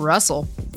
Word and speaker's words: Rusl: We got Russell. – All of these names, and that's the one that always gets me. – I know. Well, the funny thing is, it's Russell, but it's spelled R-U-S-L.